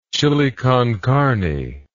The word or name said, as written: chili con carne